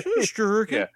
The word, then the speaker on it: Shesterkin: Shesterkin? Yeah. Just pronounce it like the Swedish chef. You'll be fine.